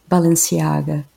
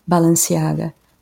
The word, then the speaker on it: Balenciaga: The first one is Spanish, and it's Balenciaga. I don't speak Spanish, but I know that's how it's supposed to be pronounced, so Balenciaga. The second one is Italian and it's Bottega Veneta. So Balenciaga and Bottega Veneta.